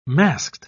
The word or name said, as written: masked